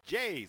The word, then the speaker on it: Jace: Jace, se escribe Hase con J y con S.